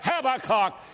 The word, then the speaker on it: Habakkuk: And all of a sudden, Dr. Paisley said, Habakkuk Habakkuk. We pronounce that Habakkuk. You will never learn English unless you let us teach you. It's Habakkuk.